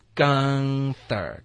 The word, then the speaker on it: Gunther: Gunther，刚刚的刚。